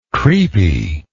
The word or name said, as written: creepiness